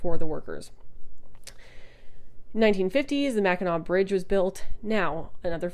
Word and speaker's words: Mackinac: So I have something on this mug that says Big Mac, even though the bridge is pronounced Mackinac, so it's really like the Big Ma.